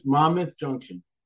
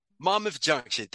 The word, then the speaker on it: Monmouth Junction: And she was from Jersey, Monmouth Junction. – It's by me. It's not far from me. – Serious? Oh, snap. Am I saying it right? – Monmouth Junction. – Monmouth Junction. Thank you.